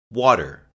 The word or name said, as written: water